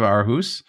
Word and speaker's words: Aarhus: And prior to joining Microsoft in 2005, he was an associate professor at the University of Aarhus. Did I say that right? – Well, that was beautiful.